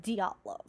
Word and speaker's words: Dyatlov: So nothing more on the Dyatlov Pass, or how do you pronounce it? – Dyatlov. – Dyatlov. – Dyatlov. – That does sound more Russian than Dyatlov.